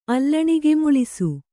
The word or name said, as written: ಅಲ್ಲಣಿಗೆಮುಳಿಸು